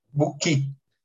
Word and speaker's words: Book: Daniel, é Book ou Bush? Como é que pronuncia teu nome, Daniel? – Book.